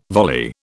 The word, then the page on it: volley: British English